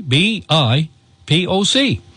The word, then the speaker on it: BIPOC: C B I P O C. It's pronounced BIPOC.